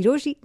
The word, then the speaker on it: Rochie: मेरा नाम है मिर्ची रोशी, नाम की स्पेलिंग है ROCHIE, और मेरे साथ लाइन पर अभी मंगेश है.